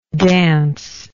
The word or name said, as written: dancingly